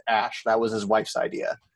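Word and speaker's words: Æ: That's pronounced Ash.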